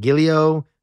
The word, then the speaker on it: giallo: I always say this wrong. Giallo, yellow is the Italian word for it.